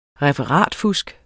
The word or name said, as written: referatfusk